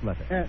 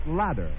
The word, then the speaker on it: letter: Well, if not, let's go on with the next letter. – Maybe I should be reading my letter, Mr. Patil. – Mr. Kaplan, yes, but please remember the word is pronounced letter, not ladder.